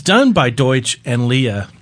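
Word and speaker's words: Deutch: It's Howard Deutsch. Am I pronouncing it right, I think? – Close enough.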